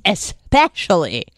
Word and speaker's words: especially: Yes, this is not a silent letter. This is an invisible letter because there's no X in especially. It's especially.